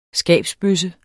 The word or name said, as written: skabsbøsse